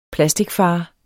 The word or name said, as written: plasticfar